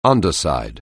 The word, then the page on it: underside: anglais